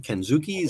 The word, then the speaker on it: Kensuke: 18 years ago, March 12th, 2004, in Tokyo, Japan, uh, Kensuke, Ken, yeah, I said that right. Kensuke Sasaki defeated Hiroshi Tenzan to win the IWGP Heavyweight Championship.